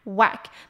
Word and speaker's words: whack: 那它有一个字呢跟它发音非常像，可是A的发音不太一样，是 whack， whack 是重击的意思，它的WH发W的音， A 是发A， whack。